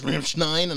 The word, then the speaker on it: Rammstein: Like, how do you pronounce that? And you're like, oh my God, it's Rammstein.